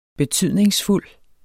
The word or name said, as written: betydningsfuld